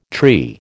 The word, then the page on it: tree: American English